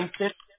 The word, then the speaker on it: Kingston: You said Kingston? Did I say that right? – Kingston.